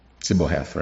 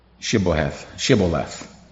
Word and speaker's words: Shibboleth: It was interesting that it's very interesting him asking for the pronunciation of the word Sibboleth, right? Shibboleth.